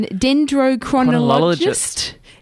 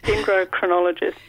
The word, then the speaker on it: dendrochronologist: So we've got your title written down, dendrochronologist. Is that correct? – Almost, dendrochronologist.